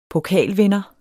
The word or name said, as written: pokalvinder